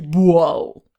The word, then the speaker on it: Boal: Und die sprechen die eine Rebsorte, die haben so eine weiße Rebsorte, die nennt sich, also geschrieben ist es Boal. Und wir würden sie wahrscheinlich auch so aufsprechen oder wahrscheinlich die anderen Portugiesen auch, aber auf Madeira selber spricht man sie Boal.